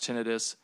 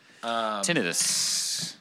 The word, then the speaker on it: tinnitus: A lot of people refer to the ringing in your ears as tinnitus, but that is actually incorrect. It is pronounced tinnitus. The suffix of itis implies swelling, which is not what tinnitus is.